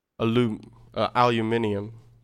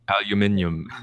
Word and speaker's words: aluminium: Also, we have to start pronouncing correctly, like aluminium. – Aluminium. – My buddy from college, Toby, he's from Nigeria, and he's got family in England as well. And aluminium, that's what it was off the bat. I had to double take. What? Wait, what did you just say? There's an extra couple sounds in there.